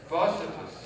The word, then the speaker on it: Barsabbas: Barsabbas.